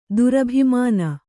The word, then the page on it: ದುರಭಿಮಾನ: Kannada